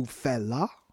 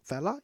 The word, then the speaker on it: Fela: So I will play for you Fela. That's how we pronounce it. Is it Fela? Yeah, Fela Kuti.